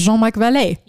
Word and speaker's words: Jean-Marc Vallée: That is how you pronounce it, is Jean-Marc Vallée.